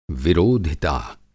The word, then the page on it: विरोधिता: Hindi